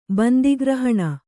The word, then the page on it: ಬಂದಿಗ್ರಹಣ: Kannada